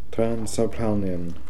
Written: Transapalnian

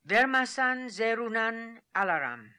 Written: Vermassan zeroonan alaram

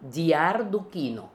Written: Diardookino

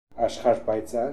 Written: Aksharpanziar

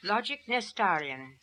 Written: Logicnestarian

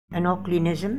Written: Anoklinism

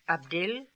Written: Abdil

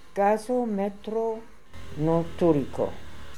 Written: Gasometronoltooriko

